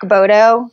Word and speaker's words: Baudot: My personal one is my name, Brooke Baudot, which B-A-U-D-O-T. I know it's nothing how it's pronounced.